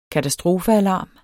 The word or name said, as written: katastrofealarm